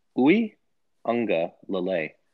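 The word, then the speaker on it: Uiagalelei: It's actually pronounced Uwe Unga Lele.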